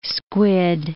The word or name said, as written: squid